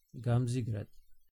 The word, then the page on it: Гамзиград: ɡǎmziɡraːd; Serbian